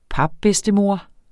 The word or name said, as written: papbedstemor